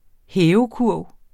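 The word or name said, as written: hævekurv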